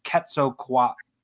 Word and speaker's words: Quetzalcoatlus: And so to hear David Attenborough say it in Prehistoric Planet, I was like, so it's basically like Atlas, and then it's Quetzalcoatlus, and she said it the exact same way.